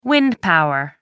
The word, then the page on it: wind power: American English